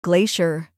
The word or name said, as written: glacier